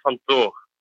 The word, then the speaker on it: Van Thor: No, it's just Americans looking at the TH and thinking that you have to make it a TH. It's say Van Thor.